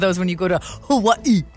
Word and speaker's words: Hawaii: A Humane Society volunteer in Hawaii. I say it like the natives.